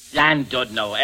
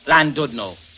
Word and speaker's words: Llandudno: Landudno. Very special. 26 and 6. – How do you spell it? – Landudno. L-L-A-N-D-U-D-N-O. Landudno.